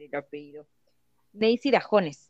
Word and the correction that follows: Dajomes: ¿no? Dajomes, Dajomes.